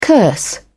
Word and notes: curse: British English

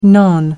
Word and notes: non: American English

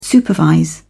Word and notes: supervise: British English

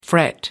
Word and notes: fret: American English

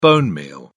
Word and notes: bonemeal: British English

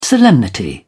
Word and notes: solemnity: British English

